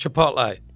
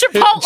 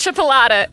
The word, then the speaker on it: Chipotle: It's pronounced Chipotle. Was that it? No. – Yeah, it's Chipotle. – Chipotle, yeah. – It's not Chipolata. It's Chipotle, and they're two different things.